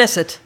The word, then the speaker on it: Knesset: Knesset.